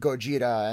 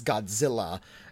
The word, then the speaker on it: Gojira: And I appreciated at the time that at least they tried to address the mispronunciation that Americans misheard the word Gojira and mispronounced it as Godzilla.